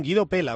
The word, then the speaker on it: Guido Pella: Guido Pella, aunque se escriba con elle. Así es como se pronuncia nuestro rival hoy,